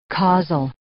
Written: causal

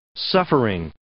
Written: suffering

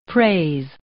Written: praise

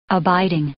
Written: abiding

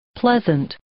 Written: pleasant

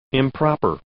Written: improper

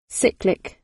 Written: cyclic

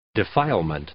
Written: defilement

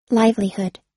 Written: livelihood

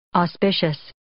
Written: auspicious

